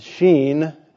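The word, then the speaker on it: Shin: They had evidently, from the inability to pronounce the SH, the Sheen, the Hebrew letter, which is pronounced SH, and their inability to do that, to pronounce it the way everybody else did, suggests they had lived in isolation.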